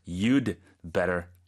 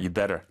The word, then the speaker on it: you'd better: 근데 발음할 때는 그 d 부분이 거의 발음이 안 들릴 정도로 you'd better 이렇게 된다는 거네요.